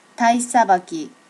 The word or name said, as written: tai sabaki